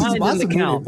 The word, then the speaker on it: Masamune: Hey, did I pronounce that right? Masamune? – Is it Masamune? – Yes, I think it is. I think that's the point. – Dang, yes, it's Masamune.